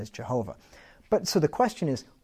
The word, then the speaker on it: Jehovah: So if you put vowels in between there, and the Y is pronounced as a J, and the W is pronounced as a V, Jehovah. That's how you pronounce God's name. It's not Yahweh, right? Yahweh is two syllables, right?